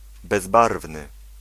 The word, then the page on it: bezbarwny: [bɛz̪ˈbarvn̪ɨ]